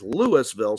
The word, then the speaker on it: Louisville: Louisville, spelled like Louisville, but Louisville, mm.